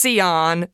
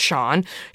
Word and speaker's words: Sian: So Shawn Richards London, and you spell her name S-I-A-N. So it looks like it's Sion, but you pronounce it Shawn.